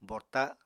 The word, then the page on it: borta: Apatani